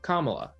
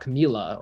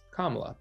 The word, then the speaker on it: Kamala: She said, like, in your country it's pronounced Kamala, you know, like that's a very interesting point that, like, she had to make that differentiation that, like, it's not Camila or whatever, it's Kamala, but it's specifically here that's how you pronounce it.